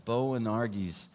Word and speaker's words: Boanerges: So James, the son of Zebedee, and John, the brother of James, to whom he gave the name Boanerges. How do you say that? Boanerges.